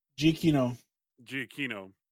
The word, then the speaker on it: Giacchino: Giacchino.